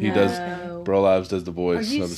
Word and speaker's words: Burl Ives: Burl Ives. – Oh yeah, Burl Ives.